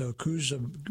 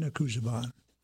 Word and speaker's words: Okhuozagbon: It's Okuzegbon, O-K-H-U-O-Z-A-G-B-O-N. And I think from now on, when I come across his name, I will call him Sean, because that is his name, Sean Okuzegbon.